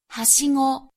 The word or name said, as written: hashigo